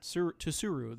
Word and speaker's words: Tsuru: Yeah, we still have the English voice actor, but R.I.P. to Hiromi Suro. I did not pronounce that right. Tsuru. There we go.